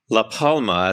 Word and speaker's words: La Palma: I'm sure there are many who's listening to this who might want to consider La Palma, as I now realize it's pronounced.